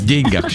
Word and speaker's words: деньгах: В общем, друзья мои, ударение на букву «е» в деньгах считается устаревшей формой, потому что употребление допустимо лишь в случае пословиц, поговорках, как, например, «не в деньгах счастье». Действительно, здесь можно сказать «не в деньгах». Во всех остальных случаях на второй слог.